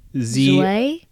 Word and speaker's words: Zouai: Z-O-U-A-I. – She's French. – She's French, Gabe said. – Lolo Zouai? – Zouai?